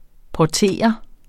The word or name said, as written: porter